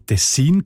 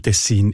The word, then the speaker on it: Tessin: Der Kantons- und Flussname hat man nämlich im Mittelalter aus dem lombardischen Dialekt vom Tessin ins Deutsche übernommen. Und in diesem Dialekt sieht man Tessin oder Tessin, also auch auf der zweiten Silbe betont.